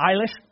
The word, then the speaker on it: Eilish: See, I don't even think I'm saying her name correctly. It was Eilish. Eilish? Yeah. Okay, thank you guys for helping me out.